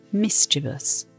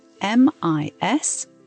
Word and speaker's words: mischievous: Mischievous. It's spelt MISCHIEVOUS and pronounced mischievous. So mischievous. What does it mean and why is it a nice word?